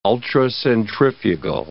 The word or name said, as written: ultracentrifugal